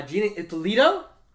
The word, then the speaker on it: Gina Ippolito: Gina Ippolito.